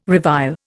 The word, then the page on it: revile: Indian